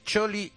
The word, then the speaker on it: Scioli: Hemos dicho Scioli, Scioli, Scioli. Es Choli y nos ha faltado Ercholi.